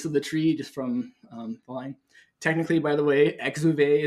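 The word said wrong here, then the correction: exuviae: is the, am I saying that right? Is the proper term for the shells, the exoskeletons they shed. – Yeah, the exuvia. – The husk. – Exuvia. Thank you.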